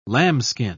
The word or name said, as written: lambskin